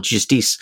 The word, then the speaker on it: Justice: I've been really enjoying a record by an artist called Gaspard Auger of the band Justice, or Justice to pronounce them properly, the French house music band.